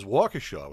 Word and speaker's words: Waukesha: Not too actually far from Kenosha in Wisconsin is Waukesha, Wisconsin.